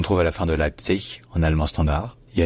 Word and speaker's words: Leipzig: Avec dans les deux cas, bien sûr, l'accent tonique qui est sur la première syllabe. Leipzig, bien Leipzig. Mais jamais avec un ge à la fin, parce qu'en allemand, le ge final se devient toujours un que.